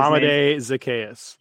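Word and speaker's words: Olamide Zaccheaus: The options here, Amir Smith-Marset from Minnesota, then Damian Williams, Jacob Harris, Dawson Knox, Olamide Zacharias, whatever, I can't always say his name. – Olamide Zacharias.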